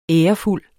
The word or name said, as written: ærefuld